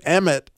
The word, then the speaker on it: Emet: It is pronounced Emet in Hebrew.